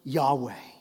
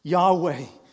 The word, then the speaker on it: Yahweh: And so the pronunciation has kind of been lost. But YHWH and the different variations even found in this text will most likely translate to the name Yahweh. Yahweh.